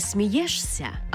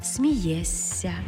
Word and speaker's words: смієшся: Не «смієшся», а «смієся».